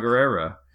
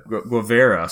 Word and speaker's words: Guevara: Chia Guerrera. – Chia Guerrera. – yeah. Guevara.